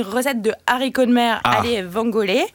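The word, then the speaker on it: alle vongole: Je ne sais pas si je l'ai bien prononcé, Nathalie. – Allez vangolé. – Voilà. Allez, bon. Italienne que moi. – Mais vous avez. – L'accent tonique est sur la première syllabe.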